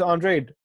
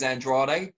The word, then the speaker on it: Andrade: Usually, if a Mexican fighter's got that surname, it is Andrade. But for him, it is Andrade. Like, I've interviewed him and he said specifically, that's how you pronounce my name.